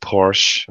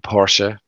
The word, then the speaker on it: Porsche: at Porsche, or Porsche, depending on how you'd like to pronounce them,